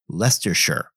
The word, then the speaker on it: Leicestershire: During last week's episode, I had been talking about the birthplace of George Fox, and I mentioned it had been in Leicestershire. Well, as it turns out, a listener pointed out that it should have actually been Leicestershire.